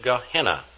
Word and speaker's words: Gehenna: First, we have the Greek gena, or we pronounce it Gehenna, always translated hell, also known as the lake of fire.